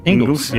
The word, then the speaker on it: Ingles: Ingles.